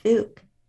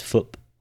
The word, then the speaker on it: Phuc: P-H-U-C. – P-H-U-C. – And the first page of the book, he's talking about how that's been mispronounced, actually. He's been going with Fook. But he says it's pronounced Foop.